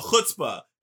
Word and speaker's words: chutzpah: Oh, chutzpah.